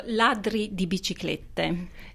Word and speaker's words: Ladri di Biciclette: So, Ladri di Biciclette. That's the Italian title. Yes. – Perfect.